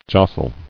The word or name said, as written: Jostled